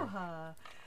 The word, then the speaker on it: Aloha: Aloha. It's spelled Aloha. A-L-O-H-A. A, yeah. but they call it, but it's pronounced Aloha.